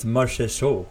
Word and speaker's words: Marchessault: It's Marchessault, I think that's how they say it.